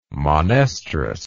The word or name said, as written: monoestrous